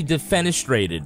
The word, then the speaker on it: defenestrated: You will not be defenestrated.